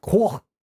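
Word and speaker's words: Coch: It's C-O-C-H, and so, but it's in Welsh. And so so the proper pronunciation is like Corfe, and it means red in Welsh, and so it's the Red Castle.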